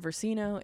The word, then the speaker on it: Versino: My name's Lauren Versino. It's spelled exactly as it sounds.